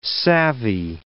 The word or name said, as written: savvy